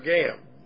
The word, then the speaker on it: gam: Moreover is the word gam, as it's pronounced, G-A-M.